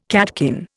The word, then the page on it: catkin: Indian